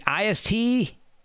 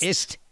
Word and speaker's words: Ist: Is it IST? Or is it the word? Is it like Ist. – Ist? Okay. Ist. I wasn't 100% sure.